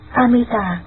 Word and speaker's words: Amita: Chữ A-di-đà, nguyên tiếng Ấn Độ đọc là Amita.